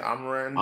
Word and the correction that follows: Amon-Ra: Amon Ra Saint.